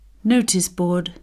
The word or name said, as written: noticeboard